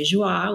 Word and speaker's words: Joi: And the women's side is Joi, and it's spelled J-O-I. So some people say Joie, which is also fancy. Joi.